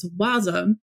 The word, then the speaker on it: WASM: Our topic today is WebAssembly, or WASM, pronounced as WASM, and or philosophy.